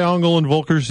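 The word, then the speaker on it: Engel & Völkers: James Dwyer with Ongle and Volkers. James, did I say Ongle and Volkers? Did I say that properly? Because that's, sometimes I think I don't. – Yeah, you nailed it there.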